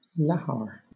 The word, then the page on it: lahar: British English